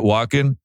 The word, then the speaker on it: Walken: Is that how you say it? Walken? – Yeah.